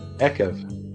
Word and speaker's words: Ekev: Next week, we are in Akev. Why are these things so hard to pronounce? Is that Akev or Akev? – Ekev. – Ekev. Fine. Ekev.